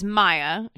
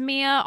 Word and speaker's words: Miya: I said her name wrong last time. I thought it was Maya. She spells it M-I-Y-A. – That seems like Maya. – Well, now that I know it's Miya, I can kind of see how it's Miya, although I still think Maya.